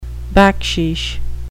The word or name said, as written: baksheesh